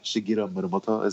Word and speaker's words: Shigeru Miyamoto: Do I sound pretentious when I say his name? By the way, Shigeru Miyamoto. Is that? – I mean, that's pretty close to accurate pronunciation, I think.